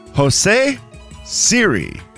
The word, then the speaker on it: Siri: Jose Siri. – And now you said it right. And so you did it. Now, when he does it, he puts the emphasis different.